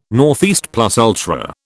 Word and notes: ne plus ultra: British English

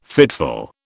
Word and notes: fitful: British English

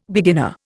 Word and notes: beginner: Indian

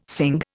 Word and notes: sing: Indian

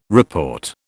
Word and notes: report: British English

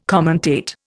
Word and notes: commentate: Indian

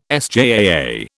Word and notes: SJAA: British English

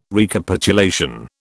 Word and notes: Recapitulation: British English

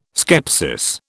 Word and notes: skepsis: British English

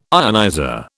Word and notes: ionizer: British English

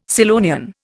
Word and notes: Scillonian: Indian

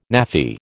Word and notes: Naffy: American English

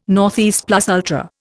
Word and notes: ne plus ultra: Indian

